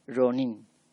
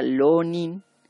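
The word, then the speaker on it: rōnin: No se pronuncia así porque en japonés no se puede pronunciar ese sonido tan fuerte de nuestra R fuerte. Y se pronuncia siempre como la R suave, como la R suave o bien como L. Entonces sería Lonin, hay una O larga ahí, o bien Ronin.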